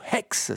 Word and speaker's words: X: Et puis on vous avait parlé en 2022 de ce film X, ou Hex, c'est pareil si on le prononce comme il faut,